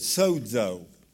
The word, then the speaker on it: sozo: The way that word is pronounced is sozo. It sounds like it has a D in it, sozo.